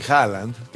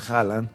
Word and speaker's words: Haaland: Erling Haaland, que el otro día me enteré porque entrevistaron a un noruego, ¿cómo se pronuncia Haaland? Ves que tiene doble A, ¿no? – ¿Haaland? – No, Haaland. – Haaland. – Haaland. Ellos es Erling Haaland. Bueno, Haaland. Para nosotros es Haaland.